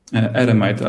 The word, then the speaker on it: Eremite: E, ja w ogóle źle akcentuję chyba, to nie jest Eremite Games, tylko jak Ty to czytasz? – Eremite, a to, to jest ja z, ja angielszczą. Ale, ale jest lepiej, tak dobra.